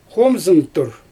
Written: xoomzɯntur

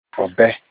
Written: ObE

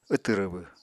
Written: wtw rwbw

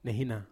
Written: nehina

